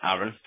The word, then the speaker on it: Aaron: As I said, my name's Aaron, spelled with two A's, by the way.